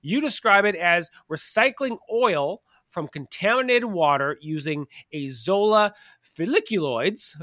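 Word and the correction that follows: filiculoides: am I saying that correctly? Folliculoids, to create a biodiesel blend. – So close, folliculoides. – Folliculoides, okay.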